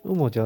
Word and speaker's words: UMO: が、えー、の楽曲、アレンジを、えー、アルバムとして、ウモと一緒にやると。そんな感じのことを、えー、やってるアルバムですね。はい。ウモジャズオーケストラの方に関しては、ウモというのは UMO と書いて、